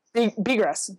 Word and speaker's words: Bigras: He tweeted, how do you pronounce Bigras? Avalanche player? – Bigras. Yeah, it's just Bigras.